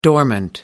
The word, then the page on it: dormant: American English